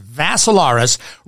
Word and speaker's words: Vassilaris: Last name is Vassilaris. Rhymes with Assilaris, except you add a V to it.